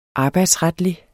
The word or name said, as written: arbejdsretlig